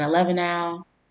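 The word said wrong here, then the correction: Avellenau: I said that wrong. Avellanau.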